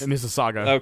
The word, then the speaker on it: Mississauga: I don't know how to pronounce this one. Mississauga? Is that how it's pronounced? – Mississauga.